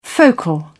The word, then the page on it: focal: British English